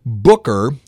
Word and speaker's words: Bukka: Booker, even though it's pronounced Booker, actually. – Uh-huh. – It's spelled B-U-K-K-A. It's a phonetic spelling of the way he used to say it. He had a Mississippi drawl. – Right. – Thus, Booker came out Booker. – Yeah. – B-U-K-K-A.